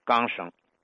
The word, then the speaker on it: 缰绳: 你比如说“ 角落”，北京方言呢读作“ 旮旯”，拴着马的那个缰绳。北京方言说成是钢声，比如老天津呢，把隔壁读成借边把隔辈人读成借辈人，把隔着门缝看人读作接着门缝看人。